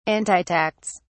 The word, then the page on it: antitax: British English